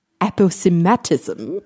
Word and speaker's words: aposematism: So it was first coined, I have that here, by British evolutionary biologist Edward Bagnall Poulton, which means that it was probably first pronounced aposematism, which is how the Audio UK pronunciation is on an etymology website.